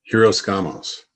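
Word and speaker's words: Hieros Gamos: Are we talking about, you know, I know you talk about who is it that you're always, is it pronounced Eros Gamos? – Hiros Gamos.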